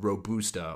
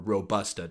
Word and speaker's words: Robusta: The other variety that is used in a lot of different types of coffee is called Robusta or Robusta, depending on how you like to pronounce it.